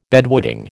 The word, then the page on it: bedwetting: American English